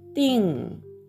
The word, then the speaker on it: 订: で、あの発音記号ですが、先ほどね、ティンの文字発音記号をお話しするの忘れていましたが、 ding、これも ng の音になります。そして発音の成長は第 4 声、上から下に急降下する音になります。ディン、ディンという音になりますが、その後にラという形声がつきますので、でんだ、でんだという発音になります。